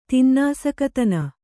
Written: ತಿನ್ನಾಸಕತನ

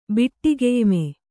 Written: ಬಿಟ್ಟಿಗೆಯ್ಮೆ

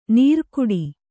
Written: ನೀರ್ಕುಡಿ

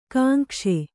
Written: ಗುಂಗಿಸು

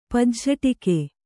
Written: ಪಜ್ಝಟಿಕೆ

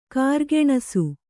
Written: ಕಾರ್ಗೆಣಸು

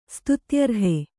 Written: ಸ್ತುತ್ಯರ್ಹ

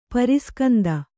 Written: ಪರಾಸ್ಕಂದಿ

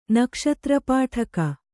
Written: ನಕ್ಷತ್ರಪಾಠಕ

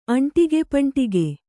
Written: ಅಂಟಿಗೆಪಂಟಿಗೆ

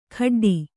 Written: ಖಡ್ಡಿ

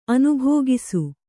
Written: ಅನುಭೋಗಿಸು

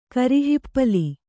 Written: ಕರಿಹಿಪ್ಪಲಿ

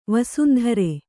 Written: ವಸುಂಧರೆ

